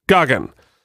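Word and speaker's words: Gaghan: You think I'd know how to pronounce his name? – Goggin.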